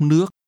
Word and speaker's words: nước: Tôi muốn uống nước. Nước. Nước. – Water is pronounced with a rising tone.